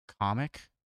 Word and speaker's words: Kamek: What was the pronunciation of Kamek we heard that recently? – Comic? It was comic, yeah.